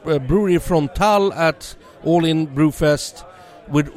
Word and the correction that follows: Brouwerij Frontaal: It's like Brouwerij Frontaal. Uh, so it's close, but yeah.